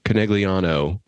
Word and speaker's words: Conegliano: Yeah, people went to Conegliano in northern Italy, if I recall what you said a second ago.